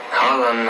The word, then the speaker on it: Haaland: Haaland, I like it more, to be honest. – Also, 2a sind ein O.